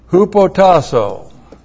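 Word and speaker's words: hupotassō: Hupotasso. H-U-P-O-T-A-S-S, long O. Hupotasso.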